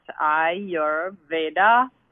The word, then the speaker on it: Ayurveda: So Ayurveda, pronounced Ayurveda, or Ved, the A is very soft, which is a natural approach.